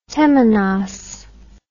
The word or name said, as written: temenos